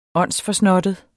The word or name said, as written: åndsforsnottet